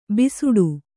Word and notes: ಭಿಸ್ಸಟೆ: kannada

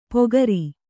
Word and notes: ಪೊಗರಿ: Kannada

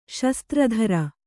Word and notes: ಶಸ್ತ್ರಧಾರಿ: kannada

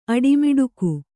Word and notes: ಅಟಮಟಿಗ: Kannada

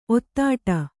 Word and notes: ಒತ್ತಡೆ: kannada

